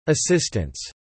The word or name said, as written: assistants